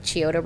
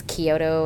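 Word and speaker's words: Chiodo: I also appreciate going to the panel for the Kyoto Brothers, because for the longest time I was pronouncing it with the CH, so like Chioto Brothers, and then they said their own name and it's Kyoto, and I was like, oh.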